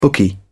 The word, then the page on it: bookie: British English